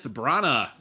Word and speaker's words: Branagh: The latest drama from director Kenneth Branagh, which kind of rhymes with drama, Belfast.